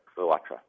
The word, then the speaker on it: Clotra: I just tell everyone make it rhyme with Sinatra. Clotra.